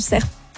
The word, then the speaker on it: cerf: Figurez-vous que j'étais dans un collège vert, donc spécialité environnement, et on nous amenait chaque année écouter le Brame du cerf. Okay. Mais du coup, arrête-moi si. Dit cerf. – Ah oui, cerf. Ouais. Ah, et dans le sud-est, on prononce toutes les lettres. – Et dis le F.